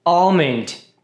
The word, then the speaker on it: almond: Almond, que é amêndoa. Esse L é bem fraquinho ou nem é pronunciado.